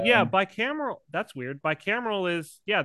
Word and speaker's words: bicameral: bicameral mentality. Father, are you familiar with this term? I know I'm butchering how to say it, but like bicameral.